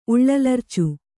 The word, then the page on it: ಉಳ್ಳಲರ್ಚು: Kannada